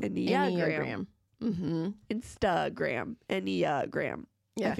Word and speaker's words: Enneagram: It's Enneagrams. Did I say it right? – Did you say Enneagrams? – No, I didn't.